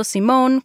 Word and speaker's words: Simone: Y sí, estoy pronunciando Simone con una E al final como nombre femenino.